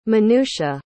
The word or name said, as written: menucha